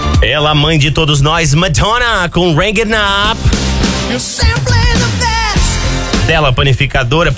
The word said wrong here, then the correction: Hang Up: Peraí que o DJ tá corrigindo minha pronúncia aqui. Como é que é? – Hang Up. – E eu falei o quê? – Rang It Up.